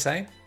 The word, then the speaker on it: Seine: How do you say it? – Seine. – The River Seine. – Yeah, okay.